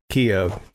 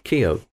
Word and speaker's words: Keough: Keough. – Keough.